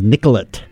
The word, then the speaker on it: Nicollet: It's like when you go to Minnesota and everything is Nicollet. You gotta pronounce that T.